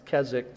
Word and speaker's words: Keswick: that's the way that's pronounced Keswick, the W is silent, or Victorious Life Movement.